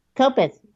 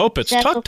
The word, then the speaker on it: Kopits: But tell me about Dr. K-O-P-I-T-S, however you would pronounce that. Tell me how about your relationship with him. Dr. Kopitz? – Kopitz, yes. – Okay, Kopitz. Talk to me about that.